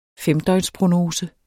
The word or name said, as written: femdøgnsprognose